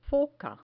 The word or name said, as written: foca